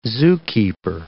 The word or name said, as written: zookeeper